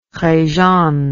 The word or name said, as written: Rejane